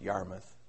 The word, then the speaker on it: Jarmuth: By the way, that is pronounced Yarmuth.